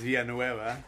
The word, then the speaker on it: Villanueva: It's Villanueva. – How say it again? – Villanueva.